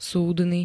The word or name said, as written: súdny